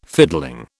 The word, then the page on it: fiddling: British English